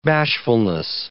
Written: bashfulness

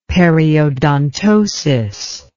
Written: periodontosis

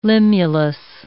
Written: limulus

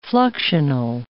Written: fluxional

